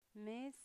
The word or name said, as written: mes